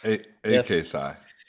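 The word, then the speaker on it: AKPsi: Can roll through how you end up at Santa Clara and AKSci? Did I say that right? – Yes. – AKSci. – AKSci. Okay. – Alpha, it stands for Alpha Kappa Psi.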